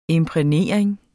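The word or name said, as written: imprægnering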